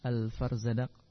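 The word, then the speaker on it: Al-Farzadaq (الفرزدق): Ya, Farzadaq, Allahu a'lam bacanya. Al-Farzadaq dengan qaf.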